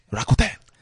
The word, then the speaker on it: Rakuten: Yeah, I have to say it like a Japanese. Rakuten.